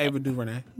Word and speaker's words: DuVernay: Ava DuVernay.